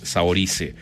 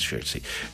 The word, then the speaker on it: Saoirse: Así se debe pronunciar en irlandés, Saoirse Ronan. Creo que es Saoirse.